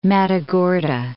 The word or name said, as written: Matagorda Bay